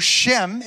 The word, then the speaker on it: Shem: The name, so Shem is how you say name in Hebrew.